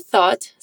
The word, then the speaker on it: thought: Para pronunciar thought, a gente começa com a pontinha da língua entre os dentes para fazer o primeiro som. e termina em ot. Thought.